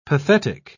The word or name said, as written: pathetic